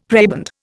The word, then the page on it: prebend: Indian